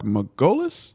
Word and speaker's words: Margolis: So we'll start off with rest in peace to, now again, correct the last name if I butcher it, I apologize, but Mark Margolis? – Yes.